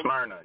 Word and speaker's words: Smyrna: You can find out where he's performing at in Smyrna, Georgia. – Smyrna, yep. – Smyrna, Georgia. Sorry, I pronounced it wrong.